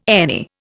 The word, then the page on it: any: Indian